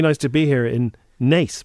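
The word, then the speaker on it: Naas: Nadine Coyle hilariously called Nace Nas, right? Oh. She didn't know how to pronounce, even though she opened a shopping centre or something in Nace. She called it Nas.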